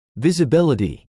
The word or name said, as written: visibility